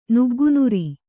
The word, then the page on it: ನುಗ್ಗುನುರಿ: nuggu nuri